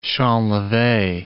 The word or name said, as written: champleve